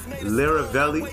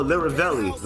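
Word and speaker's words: Lyrivelli: But this is the Lobby Boys, Mano, and Jim Jones, Lobby Boys Anthem, featuring the nigga's name I cannot pronounce, but it's L-Y-R-I-V-E-L-L-I. Respect to that guy. Lyravelli. Oh, Lyravelli. Like lyrical, but Lyravelli.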